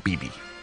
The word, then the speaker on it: bebe: Oh, that B-E-B-E? Ah, yes. – BB. Or Bebe, depending on how you pronounce it. I like to pronounce it BB.